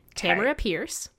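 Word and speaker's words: Tamora Pierce: Okay, I'm going to rewind you a bit. Tamara Pierce. – Yeah. Did I say Tormora? – Yes, you did. Said Tormora Price, not her name.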